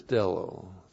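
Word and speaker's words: stello (στέλλω): It's stello, not cello. Cello, that's what they do in Texas when they get the oil out of the ground. They cello. But this is stello. It's a whole different thing. S-T-E-L-L long O. Stello.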